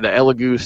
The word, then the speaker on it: Elegoo: It's the Elegoo Saturn.